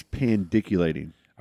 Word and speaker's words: pandiculating: I can't pronounce this word. If you ever find yourself panned, pandiculating? – Pandiculating. – P-A-N-D-I-C-U-L-A-T-I-N-G.